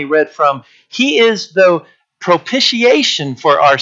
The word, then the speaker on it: propitiation: Propitiation, that's how you pronounce propitiation. I didn't know that until like last week. I didn't, in getting ready for this. I would say propitiation.